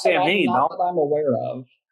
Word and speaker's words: Samhain: Well, Sam Hain is really pronounced Samhain. That's not the correct pronunciation of it, so I wouldn't count that. It's not a name. It's just Halloween in Gaelic.